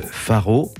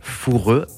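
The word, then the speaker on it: Fårö: Faro, Foureux, Foureux, tout dépend de la prononciation, l'île d'Ingmar Bergman, le cinéaste considéré comme l'un des plus grands réalisateurs du cinéma mondial, le génie du cinéma suédois.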